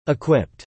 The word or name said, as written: equipped